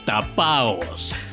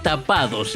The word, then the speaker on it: tapao: En esta parte de la Argentina, a las riquezas ocultas les decimos tapaos. Sí, no es tapados, ¿eh? se escribe y se dice tapao.